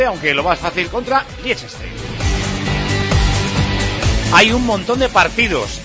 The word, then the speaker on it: Liechtenstein: Su día no se pudo jugar por la amenaza terrorista, y el lunes ya de clasificación para el próximo mundial contra Liechtenstein, o Liechtenstein, que es como se pronuncia.